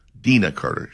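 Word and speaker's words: Deana Carter: So there, you're going to be here enjoying music from Collective Soul, Everclear, John Waite, Channing Wilson, Dina Carter, is the way she likes to pronounce it, and Mark Chestnut.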